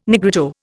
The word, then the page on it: Negrito: Indian